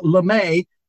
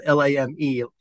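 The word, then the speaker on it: lamé: Well, LeMay is a cheap, shiny fabric. So, it's spelled LAME, like lame, but we said LeMay, LEMAY.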